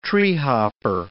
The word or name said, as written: treehopper